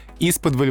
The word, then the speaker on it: исподволь: Исподволь вызывает вопросы и с ударением, и со значением, и с происхождением. Значит, ударение на первом слоге, без вариантов.